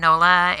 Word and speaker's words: Nola: Nola, N-O-L-A, N-O-L-A.